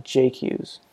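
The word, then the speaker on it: Jacques: He's got David Carr, who's a top 3 or 4 guy in the country at 157, and also either O'Toole or Jarrett Jock. Or I guess since it's Missouri, they don't pronounce it the French way. They pronounce it like Jake Hughes.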